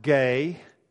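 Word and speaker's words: gay (γῆ): The word that is translated earth is, it would be pronounced gay in English. There are a few people in here who have studied Greek, so it's gamma, eta. So just the two letters. But in English, we would pronounce it gay.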